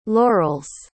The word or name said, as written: laurels